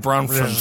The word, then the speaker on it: Bronfman: It's Bronfman. And I'll tell you what it is. It's that F. They stick right in the middle. Bronman? Got it. Bronfman.